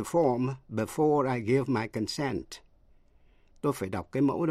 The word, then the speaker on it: consent: Hai từ nữa là concern, C-O-N-C-E-R-N, là quan tâm hay là liên hệ đến, và consent, C-O-N-S-E-N-T, nghĩa là thỏa thuận. Dù là danh từ hay động từ đều nhấn ở vần nhì và phát âm y hệt nhau.